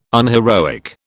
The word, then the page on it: unheroic: British English